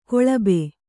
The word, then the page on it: ಕೊಳಬೆ: koḷabe